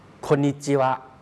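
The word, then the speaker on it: โ คนิ จิวะ (こんにちは): คนญี่ปุ่นเขาจะออกเสียงคล้ายๆเป็นเสียงตัวสะกดออกเสียงเร็วนะครับรวมกันเร็วโคกับอึก็เลยออกเสียงว่าคนครับคนเขียนสรุปเป็นเสียงภาษาไทยไว้ให้แล้วนะครับแล้วก็ขีดเส้นใต้ไว้ให้แล้วว่าออกเสียงรวมกันนะครับออกเสียงเร็วครับโคนิจิวะ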